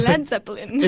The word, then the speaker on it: Led Zeppelin: Over the Hills and Far Away dei Led Zeppelin.